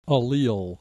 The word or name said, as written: allele